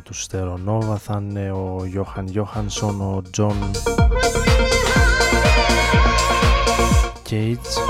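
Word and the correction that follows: Cale: Τζον Κέιλ,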